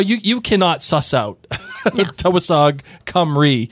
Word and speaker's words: Tywysog Cymru: Yeah, this is the title of this episode 306, is Tewasog Cymru.